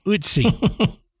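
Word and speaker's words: Ötzi: The curse of Utsi.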